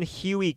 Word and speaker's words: Hughey: And on one occasion when his elder sister, Susan Huey Kemper. Not Huey, Dewey, and Louie. Like Huey, it's spelled H-U-G-H-E-Y.